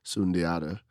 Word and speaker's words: Sundiata: I'm easily found on Facebook and Instagram, although on Facebook and Instagram, I go by my middle name, Sundiata.